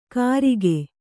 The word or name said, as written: ಕರಿಕೆ